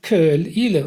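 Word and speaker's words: Caol Ila: Also die Meeresenge, und da habe ich mich mit Leuten auf Ila unterhalten, zwischen Jura und Ila, die heißt Köl-Ile und wird auch so ausgesprochen.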